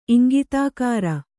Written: ಇಂಗಿತಾಕಾರ

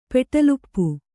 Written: ಪೆಟಲುಪ್ಪು

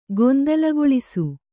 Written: ಗೊಂದಲಗೊಳಿಸು

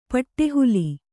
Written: ಪಟ್ಟೆಹುಲಿ

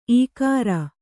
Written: ಇಗುರು

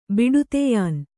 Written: ಬಿಡುತೆಯಾನ್

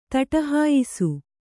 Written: ದಿಟ್ಟಿಹಾಯಿಸು

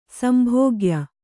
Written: ಸಂಭೋಗ್ಯ

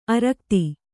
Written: ಆರಾಘಾತ

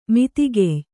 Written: ಮಿತಿಗೆಯ್

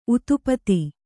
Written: ಉತುಪಾತ

